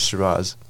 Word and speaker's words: Shiraz: When the COVID ends, visit Shiraz. Is that how you how you pr- pronounce it? Yes, yes, Shiraz.